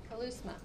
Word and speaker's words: keleusma: that word command in Greek is the Greek word kalousma. It's spelled K-E-L-E-U-S-M-A, kalousma.